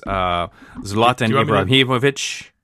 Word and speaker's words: Zlatan Ibrahimovic: Zlatan Ibrahimovic.